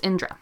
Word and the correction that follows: Indira: Indira Gandhi.